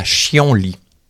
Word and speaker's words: chien-lit: À l'origine, on écrivait avec des traits d'union « chille en lit ». C'est assez clair et cela justifie la prononciation. C'est la « chien-lit ».